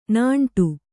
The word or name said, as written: ನೀಂಟು